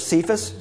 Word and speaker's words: Cephas: In some places you will see Peter referred to by the name, we pronounce it in English, Cephas, C-E-P-H-A-S. It's an Aramaic word. It probably has a hard K sound at the beginning.